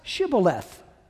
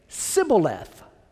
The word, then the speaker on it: Shibboleth: And if he said no, they would say to him, then pronounce Shibboleth. And he would say, Sibboleth, for he could not pronounce it right.